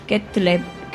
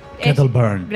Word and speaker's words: Kettleburn: Y además te encontrarás con el temerario amante de las bestias mágicas, Silvanus Ketlum. – Eso ha sonado muy mal. – Es que yo creo que lo pronuncio bien. – Kettleburn. – Kettleburn.